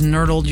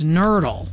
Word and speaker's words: nurdle: Nurdle. N-U-R-D-L-E. Spelled like hurdle, but with an N in front. Not like nerd, N-E-R-D. It's nerdle.